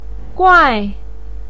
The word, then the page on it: guai4: pinyin